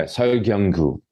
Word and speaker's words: Seol Kyung-gu: And I was also going to ask about, make sure I get the pronunciation right, Seol Kyung-gu. – Yeah, Seol Kyung-gu in Korean.